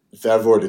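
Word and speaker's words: Vervoordt: And that's spelled H-J-O-R-T-H is the surname, isn't it? Is that how you say it? – Vervoort, it's V-E-R-V-O-O-R-D-T.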